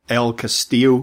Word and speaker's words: El Castillo: I think this is the uh, El Castillo cave.